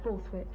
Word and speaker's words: Borthwick: Borthwick. – Could you spell that for me, please? – B-O-R.